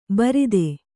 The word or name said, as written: ಬಾರೂದು